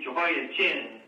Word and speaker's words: Jehoiachin: Well, it's easy to mix him up with Jehoiakim. He's Jehoiachin. Picture the guy with, you know, a big chin, but that's not the case here.